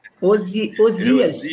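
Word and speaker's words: Oseias: E Isaías, né, diz, eu estou dando o nome do homem de novo. – Osaías. – Não é Isaías. – Osias. Eu falando errado, Osias.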